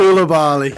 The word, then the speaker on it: Koulibaly: Koulibaly.